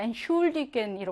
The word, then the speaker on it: Entschuldigen: 두 개가 지금 무슨 말이냐면 태 S체하가 하나가 아니라 태 따로 S체하 따로야. 그래서 뭐 태는요 그냥 트로 발음하겠죠? 그리고 S체하는요 슈로 따로 발음하겠지? 이렇게 취가 아니라 트 슈로 따로 발음합니다. 엔슐디겐 이렇게요.